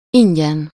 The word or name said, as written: free